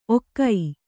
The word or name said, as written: ಔಘ